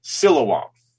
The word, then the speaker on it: Siloam: And Google told me Siloam. And then you told me Silawam. Right? Yeah. So so it's Sil- Silawam.